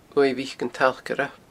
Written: uaimh mhic an tstalcaire

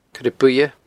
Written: coirebuidhe

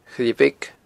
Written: A Choille Bheag

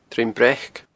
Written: Druim Breac

